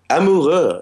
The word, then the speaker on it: Amoureux: In the song, and I forgive me if I'm mispronouncing it, is it Amourou? – Amoureux. It's like French.